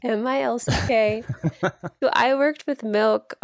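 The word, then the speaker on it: MILCK: So the example, one of my PR clients from last year is an artist named Milk, and she released her song Quiet.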